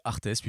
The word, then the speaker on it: ARTES: Artes ou Art, je ne sais pas comment on pourrait dire ça, c'est Artes plutôt parce que ça s'écrit en majuscule A-R-T-E-S.